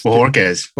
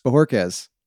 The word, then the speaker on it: Bojorquez: Bojorquez. – Bojorquez. That's what I thought. – A lot of people get confused with the uh, the Kez. They want to say Quez.